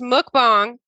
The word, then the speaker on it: mukbang: It's pronounced mukbang.